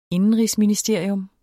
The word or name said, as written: INDENRIGSMINISTERIUM